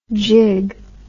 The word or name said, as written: jigg